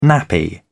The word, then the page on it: nappy: British English